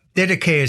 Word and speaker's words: Didache: I suppose you could be really pedantic and call it the Didache, but Didache is the most common pronunciation, and it's the teaching of the Lord through the 12 apostles for the Gentiles.